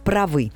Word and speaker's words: правы: А вот во множественном числе вы, мы, они правы, ударение возвращается на первый слог. И вот это, конечно, сложность. Запомнить, что права, но правы.